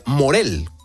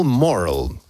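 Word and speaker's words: Morrall: Earl Morrell. – Digamos con acento en la O, ¿no? Y ella dijo Morel.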